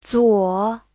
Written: zuo3

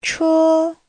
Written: che1

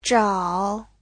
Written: zhao3

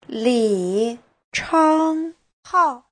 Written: li3chang1hao4

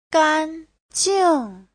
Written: gan1jing4

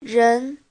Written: ren2

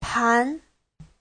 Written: pan2

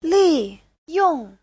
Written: li4yong4

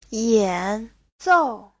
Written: yan3zou4

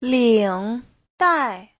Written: ling3dai4

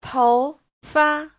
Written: tou2fa5

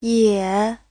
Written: ye3

